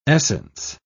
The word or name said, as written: essence